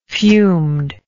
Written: fumed

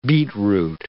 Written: beetroot

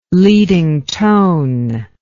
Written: leading tone

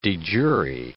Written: de jure

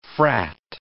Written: frat

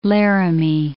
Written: Laramie

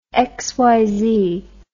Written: xyz

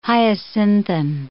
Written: hyacinthine

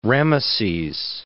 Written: Rameses

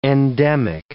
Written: endemic

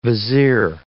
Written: vizier

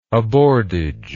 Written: aboardage

